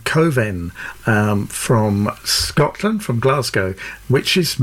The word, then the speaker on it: Cwfen: So there we are, Coven, which isn't spelt anything like it sounds. C-W-F-E-N is how you spell it, but it's pronounced Coven, apparently.